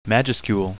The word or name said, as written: majuscule